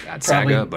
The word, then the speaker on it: Atxaga: So Bernardo Atxaga, that's spelled A-T-X-A-G-A. So it took me a little while to figure out how to pronounce it. I was going to go with Atxaga, but it's Atxaga.